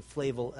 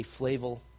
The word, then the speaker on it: Flavel: Some pronounce his name Flavel, others Flavel. I'll say Flavel.